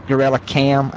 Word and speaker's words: GKrellMKam: Only thing I use it for is I use a plug-in called GorillaCam, G-K-R-E-L-L-M-K-A-M, GorillaCam. I may be spelling it wrong, but that's how it's pronounced.